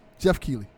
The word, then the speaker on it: Keighley: Jeff Keighley.